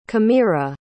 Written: camira

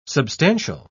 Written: substantial

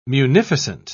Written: munificent

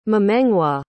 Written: memengwa

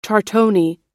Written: tortoni

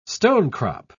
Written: stonecrop

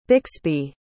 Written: Bixby

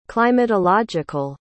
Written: climatological

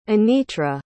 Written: Anitra

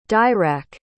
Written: dirac

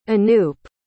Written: anoop

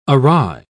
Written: awry

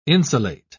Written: insulate

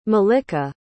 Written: mallika

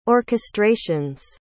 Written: orchestrations

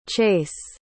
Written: chayce

